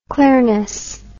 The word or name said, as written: clearness